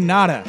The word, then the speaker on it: Noda: Ryan Noda has like the least MLB name I've ever heard. Like, there's a player. – It's Ryan Noda, actually. – Noda. – It's pronounced. – Noda.